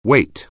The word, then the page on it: weight: anglais